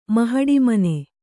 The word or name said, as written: ಮಹಡಿಮನೆ